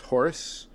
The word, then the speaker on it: Rey Horus: Horus? Spanish, you don't pronounce the H.